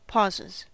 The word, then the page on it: pauses: American English